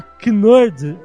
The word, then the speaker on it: knerd: E como K, com K-N, é mudo em inglês, né? Se você botar knerd, então a palavra seria nerd.